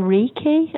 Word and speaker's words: Reiki: And basically, I think we've decided we're going to follow on next time we do this awesome chat with cranial, sacral, and Reiki. Am I saying that right? – Yes, that's correct.